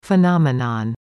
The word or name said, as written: phenomenon